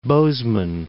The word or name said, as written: Bozeman